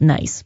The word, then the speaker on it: Nice: When I looked it up after, it is spelled nice, but pronounced Nice.